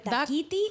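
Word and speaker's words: Dákiti: Is it Dakiti? – Dakiti? Pues tiene el acento en la A.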